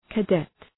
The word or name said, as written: cadet